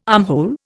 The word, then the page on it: armhole: Indian